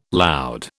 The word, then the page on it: loud: British English